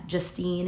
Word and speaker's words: Justine: Justine is J-U-S-T-I-N-E.